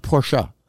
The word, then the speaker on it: Porsche: It's pronounced Porsche. That's French. That's French. It's German. Porsche. – I'm going to say Porsche. – It sounds sounds elitist to say Porsche, but it actually is pronounced Porsche.